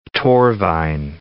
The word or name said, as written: Torfaen